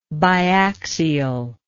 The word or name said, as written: biaxiality